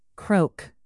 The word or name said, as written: croak